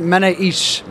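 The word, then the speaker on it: Manoushe: Yeah, so we pronounce it Manoush. – Manoush. This is an Australian telling me how to pronounce it.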